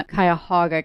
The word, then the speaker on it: Cuyahoga: So they're up in the Cuyahoga County. I learned how to say that correctly since I came to the Attorney General's office. – You got it. It's perfect.